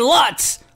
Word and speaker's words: Lutz: But I mispronounced their city. Wait, I'm going to do this again. Make sure you listen to us on affiliates. Time 102.9 WTYM in Lutz, Florida. Now, the Iceman, like a klutz, said Lutz last week.